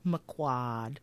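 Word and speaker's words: McQuoid: She says quad. It's like quad. It's only got one syllable. Quad. Actually, two. Because it's McQuad.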